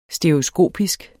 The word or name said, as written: stereoskopisk